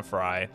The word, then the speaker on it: Frey: I think I was saying her name wrong when I was saying Fauna Frey. I believe it's Fauna Frey. It's F-R-E-Y. And her first name is Fauna, F-A-U-N-A.